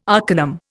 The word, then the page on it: arcanum: Indian